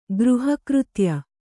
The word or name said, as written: ಗೃಹಕೃತ್ಯ